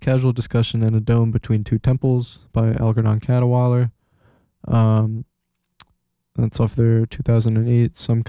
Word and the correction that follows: Cadwallader: Man, I always say it wrong. Algernon Cadwallader.